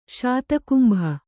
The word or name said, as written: ಶತಕುಂಭ